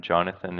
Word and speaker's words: Jonathan: Yeah, so best way is email jonathan.king, and Jonathan is spelled J-O-N-A-T-H-A-N.